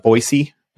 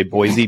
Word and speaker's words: Boise: Absolutely fantastic group of folks over there that run Everly Stock out of Boise, which I've been told is the proper way to pronounce it. If you say Boise, people shame you for it, and it makes me sad.